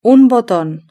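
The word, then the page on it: un boton: Spanish (Spain)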